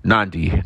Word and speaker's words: Nadi: And we got to Fiji at the sun was just rising when we arrived in uh, Nadi, Nandi, not Nan, not Nadi, Nandi. It's spelled N A D I, so that's why I said Nadi but it's Nandi.